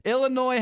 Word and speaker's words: Illinois: Illinois has a silent S sound, so it should be pronounced Illinois, not Illinois.